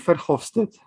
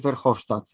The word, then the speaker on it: Verhofstadt: No jest to polityk z Beneluxu Guy Verhofstadt, czy Verhofstadt, w zależności by to wymawiać.